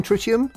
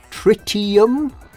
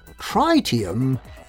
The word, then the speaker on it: tritium: It can be pronounced in one of two ways, tritium or tritium. The pronunciation tritium is incorrect, and anyone heard using this form must try harder.